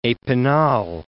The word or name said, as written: epinal